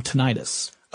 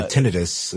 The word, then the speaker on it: tinnitus: Also, I thought I'd ask you, you're familiar with the term tinnitus. – Oh, yes. Or tinnitus, as some people pronounce it.